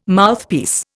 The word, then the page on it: Mouthpiece: Indian